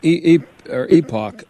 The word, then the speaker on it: Epoch: I never know if I'm pronouncing it right. Is it Epoch? Epoch. – Either one. They're tolerant towards either.